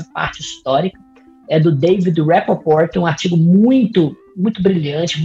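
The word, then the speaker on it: Rapoport: inclusive, na, na, na, série, já que esses grupos eram muitos, esses grupos ditos terroristas da terceira onda do, do Rappaport, era muito utilizada pelos, é, pelos narcotraficantes como massa de manobra, é, é para deixar o, o, Estado ocupado, principalmente.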